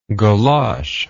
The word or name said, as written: golosh